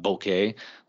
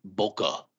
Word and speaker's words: bokeh: And not, but like, yeah, I can see how that would be a weird pronunciation. But bokeh, like, no one ever said that growing up. Only with the opening up of information and communication internationally did we hear it pronounced differently. Of course, some asshole in L.A. or New York is going to be like, no, I only heard bokeh my entire life.